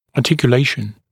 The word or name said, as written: articulation